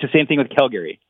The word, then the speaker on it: Calgary: See, I'm being made fun of by listeners because I pronounce the city the way you've told me to say it, Calgary.